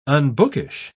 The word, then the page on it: unbookish: British English